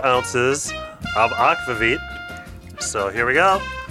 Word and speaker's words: aquavit: And we now know how to pronounce aquavit. – Aquavit. – Yes. So I've heard aquavit. I've heard aquavit. But now we know from an expert.